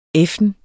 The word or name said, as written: effen